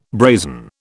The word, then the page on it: brazen: British English